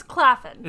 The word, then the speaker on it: Claflin: But also, it's Claflin. – Is it Claflin? – Yeah. – It makes sense. – It's a short A.